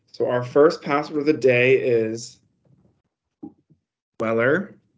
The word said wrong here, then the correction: Bueller: B-U-E-L-L-E-R, Weller. All right. – I would pronounce it Bueller.